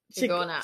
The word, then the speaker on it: Chingona: Chingona? – Chingona.